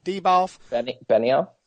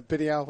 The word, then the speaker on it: Benioff: And it's worth noting, too, that I was reading an interview with Weiss and Deboff or whatever. Deboff? – Benioff? – Yeah, Benioff, whatever.